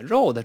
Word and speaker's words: road: 这个 road 很多同学容易把它误读成肉的，这个不对啊， road 这不是包子，那还素的呢啊。